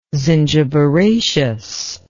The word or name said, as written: zingiberaceous